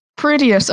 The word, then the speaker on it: Prodeus: Okay, P-R-O. – Yeah. – D-E-U-S. – Proteus. – Proteus.